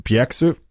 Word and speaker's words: Bjergsø: Also ja auch alles relativ frisch, also neu entstanden, 2003 von einem Mikkel Bjergsø, ich weiß nicht genau, wie spricht man das O aus mit dem Strich durch? – Ich glaube, wie ein Ö. – Mikkel Bjergsø